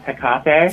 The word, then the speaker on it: Hecate: Well, first off, the goddess's name is pronounced Hecate. – Thank you. – Thank you. Perfect.